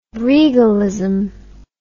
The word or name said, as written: regalism